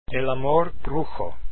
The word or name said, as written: amor brujo